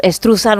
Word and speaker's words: Struzan: Bueno, pues Drew Struzan o Struzane, o como cada uno lo quiera pronunciar, nosotros decimos Struzan. – Struzan, que es más fácil.